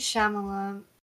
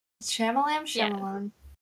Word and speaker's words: Shyamalan: Night Shyamalan. Shyamalan.